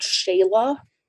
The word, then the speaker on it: Xela: Well, the first stop that I really took was in this city called Xela, spelled like X E L A, and that's in Guatemala.